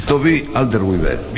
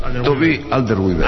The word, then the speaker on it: Toby Alderweireld: Toby Alderweireld. – Bien. – Toby Alderweireld.